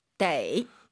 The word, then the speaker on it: 得: 떠라고 읽을 땐 동사 뒤, 동사 앞에 있을 땐 데이.